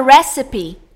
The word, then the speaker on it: recipe: Aí na palavra que tem que pronunciar a letra e, o pessoal fala cough. – Ó, e não é só coffee, a palavra recipe também. – Recipe.